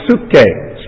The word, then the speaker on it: psuche: In the New Testament, we have the word psuche, spelled with a P at the beginning, like psychology. The word psychology comes from the Greek word for soul.